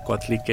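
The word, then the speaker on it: Coatlicue: Yeah, so I pronounce her name a little different. I pronounce it Coatlique,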